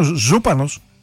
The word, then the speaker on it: Ζούπανος: Για τον Τραμπ, της Αμερικής, τι είναι ο Ζούπανος? Έτσι τονίζεται.